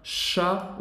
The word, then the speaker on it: chat: Não é para pronunciar o T no final. Um gato, um chat, o T é mudo.